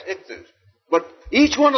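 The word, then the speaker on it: ichthus (ἰχθύς): The Greek word for fish is ichthus, spelled with an iota, which is our equivalent to our English I. And then it has a chi or chi, which is an X that corresponds to a strong K.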